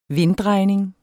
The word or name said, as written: vinddrejning